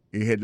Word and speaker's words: ahead: Ahead, là, c'est A-H-E-A-D. Ahead. Ahead. Go ahead. Là encore, on a point que accent tonique là tombé sous deuxième syllabe. Là, son mot gen deux syllabes.